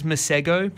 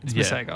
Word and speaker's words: Masego: I've seen it as Masego, but that's not right. Masego. I've seen him pronounce it and I'm taking his word.